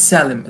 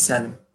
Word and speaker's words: Salim: If not, so close. – Is it Salim? – It's Salim. It's Salim. – Salim? Okay. I wasn't sure. So that's the United States version, you know, and, you know, I just want to say thank you so much for sharing these moments with me.